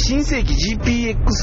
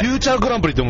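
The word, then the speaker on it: 新世紀GPX: そしてこれらの歌の部分の大部分の作詞を、うん、新世紀 GPX サイバーフォーミュラーゼロのオープンで。それはね、読み方違うんですよ。 – 違うんですか、これ。 – フューチャーグランプリって読むんですよ。新世紀 GPX で。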